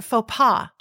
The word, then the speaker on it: faux pas: He was going for faux pas.